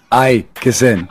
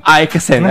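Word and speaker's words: Elkeson: Fala aí. Aikesen. Aikesen, é exatamente isso.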